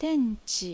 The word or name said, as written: Tenchi